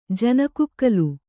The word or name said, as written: ಜನಕುಕ್ಕಲು